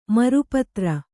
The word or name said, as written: ಮರುಪತ್ರ